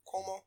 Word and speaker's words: Cuomo: Cuomo.